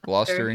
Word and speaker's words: Gloucester: Is it Gloucester? Is that how you say it? – Yeah. – Gloucester, England? – Yeah.